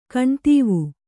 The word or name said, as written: ಕಣ್ದೀವು